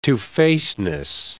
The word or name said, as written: two-facedness